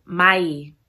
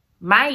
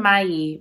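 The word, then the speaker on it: Ma'ii: It's Mai. Mai is how you say coyote in the Navajo language. She calls him Mayi, but she also calls him Coyote.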